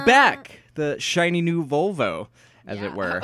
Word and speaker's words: Volvo: Yeah, as I said, Volvo. – The long O.